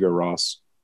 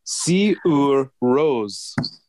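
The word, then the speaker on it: Sigur Rós: go Ross. – Go on. – Apparently, according to The Guardian, Sigur Rose.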